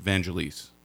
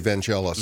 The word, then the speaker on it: Vangelis: I've always pronounced it Vangelis. – Okay, I say Vangelis. – Vangelis, I know.